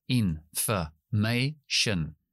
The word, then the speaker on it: information: O, information information. Tenemos cuatro sílabas, information, y yo acentúo una de ellas. Es la tercera, information. La i va al principio, f es schwa y shen también es schwa.